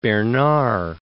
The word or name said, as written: Bernard